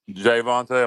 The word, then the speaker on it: Javonte: So Javonte, am I saying his name wrong? – Yeah, Jay, like a man's name, Vonte. – Javonte, not Javonte, but Javonte.